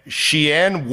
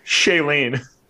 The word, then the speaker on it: Shailene: Aaron Rodgers and Shianne Woodley reportedly called off their engagement. I have no idea if I'm saying her name correctly, nor do I care. But, well, how do you say it? – Shailene.